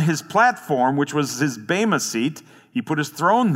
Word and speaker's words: bema: As the judgment seat of Christ, the Greek here is the word bema, B-E-M-A, or bema is the Greek pronunciation.